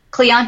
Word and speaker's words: Kleon: I think I started Instagram two years ago, and I read Austin Cloyan's book. – Kleon? I think that's how you say it. Kleon, yeah.